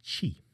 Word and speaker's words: Qi: Some people describe it as Qi, but it's actually Qi.